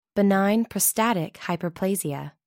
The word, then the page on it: benign prostatic hyperplasia: American English